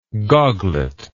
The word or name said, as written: goglet